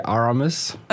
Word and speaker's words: Aramis: In English, I usually say Aramis.